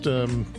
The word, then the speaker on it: XRISM: Yes, it's pronounced ZRISM, which is X-R-I-S-M, ZRISM.